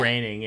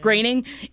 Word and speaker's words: Groening: In the 90s, Simpsons creator Matt Groening, how do you say it? I always forget. – Groening, yeah. – Groening.